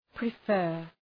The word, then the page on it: prefer: prı’fɜ:r